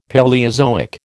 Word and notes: Paleozoic: American English

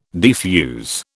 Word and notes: defuse: British English

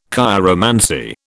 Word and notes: chiromancy: British English

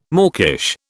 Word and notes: mawkish: British English

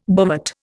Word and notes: bovate: Indian

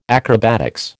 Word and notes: acrobatics: American English

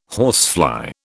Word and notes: horsefly: British English

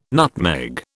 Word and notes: nutmeg: British English

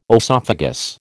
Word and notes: oesophagus: American English

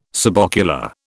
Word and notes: subocular: British English

